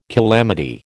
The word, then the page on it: calamity: American English